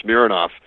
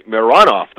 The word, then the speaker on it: Mironov: And it's funny, some of the things you remember, when the Rangers acquired one of the Mironov brothers, Boris Mironov, and if you recall, he had a brother, Dmitry, who went by Mironov. The brothers actually pronounced their names differently, Mironov and Mironov.